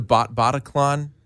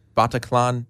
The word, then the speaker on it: Bataclan: It was one of the songs that Eagles of Death Metal played during their show at, is it pronounced the Bataclan? Bataclan? – You know, I think you're right.